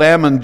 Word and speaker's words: almond: There's a candy bar called Almond Joy. That's almond. We know it as almond, but the L is really silent, just like salmon. The L is silent.